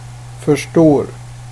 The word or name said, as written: förstår